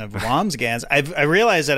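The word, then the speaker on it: Wambsgans: Yeah, I said Wamsgams. It's Wamsgans. – It's Wamsburger. Oh, is it? Okay. – No, there's an N or an M. It's hard to say. It's just two syllables. But Wamsgans.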